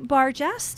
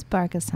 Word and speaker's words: Bargas: Okay, and then we've got Marie Bargas. Did I say it right? – It's Bargas, honey.